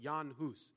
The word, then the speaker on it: Jan Hus: Recently I was reading about a man named Jan Hus, or you'll maybe see his name is Jan Hus, but it's pronounced Jan Hus.